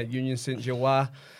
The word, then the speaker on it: Union Saint-Gilloise: Union Saint-Gilloise and PSV Eindhoven.